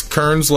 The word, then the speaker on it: Cairns: It's Kearns Law, and my last name is spelled C-A-I-R-N-S.